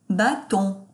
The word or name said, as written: baton